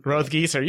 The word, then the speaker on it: Rothgiesser: I'm like 1 for 7 in pronouncing... – Hans's last name? Hans Rothgieser. Rothgieser. You got it.